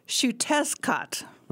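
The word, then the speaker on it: Xiuhtezcatl: And his name looks Mayan. – Mm-hmm. – And is pronounced Shutescat.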